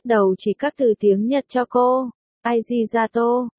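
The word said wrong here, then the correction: arigato (ありがとう): Leo đánh vần, no, arigato. Mayer nghe cách phát âm sai. Nhíu mày chỉ lại, arigato, Leo cố gắng đọc, sai, là arigato, đọc lại, ariga, cái tô, không, không có cái tô, arigato, ari, và thế là cuộc giảng dạy ngôn ngữ phát âm giữa Mayer và Leo bắt đầu.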